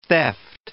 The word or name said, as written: theft